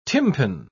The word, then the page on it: tympan: British English